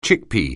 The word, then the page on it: chickpea: English